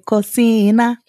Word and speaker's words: cocina: How do you say kitchen? Mesa de... Is that? Yeah. Mesa de cocina.